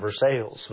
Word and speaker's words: Versailles: Versailles, Kentucky is spelled like Versailles, but since it's Kentucky, we always called it Versailles.